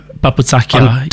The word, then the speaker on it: papoutsakia: Now, let's get on to, you've gone with your, is it papoutsakia? – Papoutsakia, I'm butchering these words.